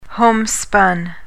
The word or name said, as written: homespun